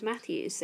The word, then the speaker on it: Matthews: People also, even though it's a very British surname, or English, I should say, surname, people struggle with Matthews. They struggle spelling it, and they struggle pronouncing it, which, you know, obviously I would take that for granted as it's my surname, so it just rolls off the tongue. But people do call me Matty Hughes, and I'm just like, where did the why come from?